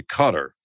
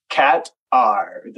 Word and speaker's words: Qatar: Whether it's Qatar or, as locals say, Cutter. – Yeah, I think it is actually Qatar. So Qatar, because the T is part of the first syllable. At least that's how most of the locals down in that part of the world seem to pronounce it. So Qatar.